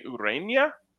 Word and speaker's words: Ureña: They've got, correct me if I'm wrong here, Jose Ureña on the mound. – Yep. Great job pronouncing that. Enye.